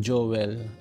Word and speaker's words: Joel: Pero sino ba si Billy Joel? Dito kasi sa Australia, yung Joel, ang pronunciation Joel. Kaya kung minsan, hindi malamang pangalan na yun, Joel.